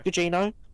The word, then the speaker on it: Gugino: Gugino.